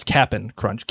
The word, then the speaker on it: Cap'n Crunch: First of all, it's Cap'n Crunch. Cap'n Crunch Get your name right.